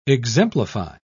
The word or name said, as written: exemplify